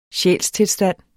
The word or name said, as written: sjælstilstand